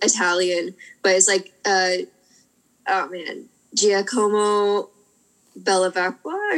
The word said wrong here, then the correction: Bevilacqua: I feel like I said that completely wrong. – No, though. You're pretty close. I happen to. I think know how to pronounce it because I'm Italian. Okay. So I think it's Giacomo Bevilacqua.